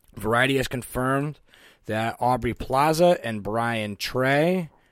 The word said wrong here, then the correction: Tyree: Am I saying that right? – Looks like Tyree. – Is it Tyree? – Yeah.